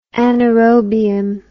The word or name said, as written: anaerobium